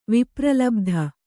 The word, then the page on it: ವಿಪ್ರಲಬ್ಧ: vipralabdha